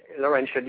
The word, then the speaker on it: Laurentian: Laurentian, yes.